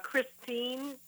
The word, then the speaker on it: Christine: It's Christine, C-H-R-I-S-T-I-N-E, middle initial E for Elizabeth, and last name Blum, B-L-U-M.